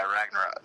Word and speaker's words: Ragnarok: Yeah, Ragnarok.